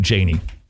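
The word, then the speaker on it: Jani: This is a great quote from I believe it's pronounced Janie Ortland. It's J-A-N-I.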